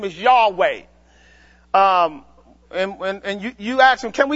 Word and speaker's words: Yahweh: That's how you pronounce his real name. Yahweh.